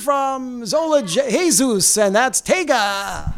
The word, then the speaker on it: Taiga: T-A-I-G-A.